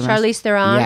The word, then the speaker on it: Charlize Theron: I was thinking Charlize, how do you pronounce her? – Charlize Theron. – Yes.